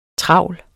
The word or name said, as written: TRAVLERE